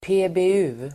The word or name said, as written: PBU